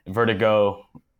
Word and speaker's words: vertigoh: Vertigo, I know, is not spelled with an H at the end. I did that because my last name is Go with an H and I thought it'd be funny. And now it's biting me in the ass whenever I say it in words, because the H is silent and people are just like, but anyway, it is what it is.